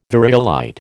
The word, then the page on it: variolite: American English